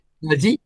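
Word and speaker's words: Disasi: Dizazzi. – Dizazzi.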